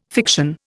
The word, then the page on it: fiction: Indian